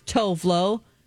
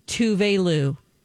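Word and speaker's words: Tove Lo: And you pronounce Tovlo Tuve Lou.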